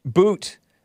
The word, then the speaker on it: Bout: You think Bout is one of the worst? – Boot. Victor Boot. Yeah, Bout is how it's spelled.